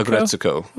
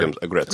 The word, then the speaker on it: Aggretsuko: How do you pronounce it? Aggretsuko? – Aggretsuko. – Okay. – So the character's name is Retsuko. So add ag to the beginning, like aggressive. – For sure. – Aggretsuko.